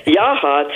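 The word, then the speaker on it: Yachats: Am I saying that right? – No, you're not. And I was testing you. It's Yachts. It's the Central Oregon coast between Newport and Florence.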